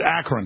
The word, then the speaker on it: Akron: Akron. Oh, Akron, of course. Wednesday, the U.S. Centers for Disease Control and Prevention notifies health officials that the second healthcare worker in Dallas diagnosed with Ebola had visited Akron.